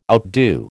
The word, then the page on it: outdo: American English